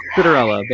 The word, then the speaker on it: Citarella: Citarella, but Citarella.